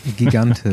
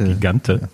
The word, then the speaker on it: Gigante: Da sehe ich eher ihre Teamkollegin, wie wird man die jetzt aussprechen? Sarah Gigantisch. – Gigante. – Gigante.